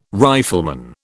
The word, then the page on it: rifleman: British English